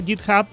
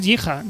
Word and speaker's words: GitHub: Sí, es que como en esta inglés hay tanta pronunciación parecida, GitHub, y no es Jija, no es ni...